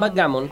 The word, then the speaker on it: backgammon: ¿Está bien pronunciado? Backgammon. – Backgammon. – Bien.